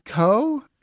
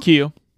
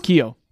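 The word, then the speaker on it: Keough: So yeah, Riley Coe, is that how you say her name? Cow? – Keo. – Q? – Keo. Keo. – Keo.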